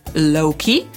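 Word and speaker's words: Loki: Jego imię akcentujemy na pierwszą sylabę, a więc mówimy low-key, a to słowo zaakcentujemy raczej na drugą, czyli low-key, choć nie zawsze będzie to bardzo dobrze słychać. I teraz co ma na myśli młodzież, kiedy mówi na przykład, że ktoś jest low-key embarrassed?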